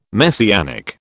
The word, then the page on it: Messianic: British English